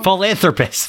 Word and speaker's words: philanthropist: This guy, they let him walk the street as a full-on rapist? – Am I pronouncing that wrong? – Philanthropist. Full-on rapist. Philanthropist. Not therapist, philanthropist. Philanthropist.